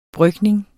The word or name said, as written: brygning